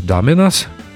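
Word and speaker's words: Dominos: Бас-гитаристы Клаус Вурман, старый друг группы Битлз, и Карл Реддл, будущий участник Derek and the Доминос. Извините, Доминос, часто ошибаюсь. Часто делаю неправильное ударение в этом слове.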